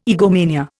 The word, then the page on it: egomania: Indian